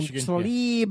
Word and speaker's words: Tlaib: Tlaib.